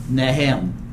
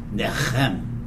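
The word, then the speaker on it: nacham: And it's the Hebrew word of N-A-C-H-A-M. N-A-C-H-A-M. Pronounced Nahem. Nahem. If you're going to be a good Hebrew person, you got to get that guttural thing in there, so I'll do one more time. Nechem.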